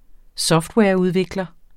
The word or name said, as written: softwareudvikler